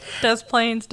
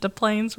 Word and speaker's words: Des Plaines: I always say this wrong. Des Plaines? – So do I, which is why I set you up to say this paragraph. Thanks. Des Plaines, Des Plaines.